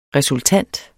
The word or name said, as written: resultant